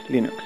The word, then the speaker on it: Linux: Hello, this is Linus Torvalds, and I pronounce Linux as Linux as Linux.